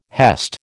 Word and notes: hest: American English

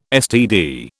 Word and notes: STD: British English

